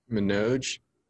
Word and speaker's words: Minogue: Minogue.